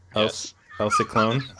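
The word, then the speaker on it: El Ciclón: Well, it has the tilde above the no, that's not tilde. – That's just an accent. – Or just the accent. – Yeah, to stress the last syllable in Ciclone.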